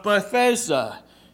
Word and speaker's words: Bethesda: There's the pool of Bethesda, and there's different spellings for this the city, but Bethesda is how you pronounce it, and it was a pool.